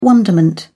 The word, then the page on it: wonderment: British English